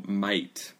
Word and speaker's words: might: G-H는 원래 발음한 걸로 알고 있어요. 그래서 이제 Might 돼버렸지만 그것도 뭐 마이프트인지 아니면 F로 했는지 아니면 G 마이프트 이렇게 어떻게 했는지 모르겠지만 귀찮아서 없어진 거죠. 네, 그래서 지금은 다 그냥 마이트처럼 해요.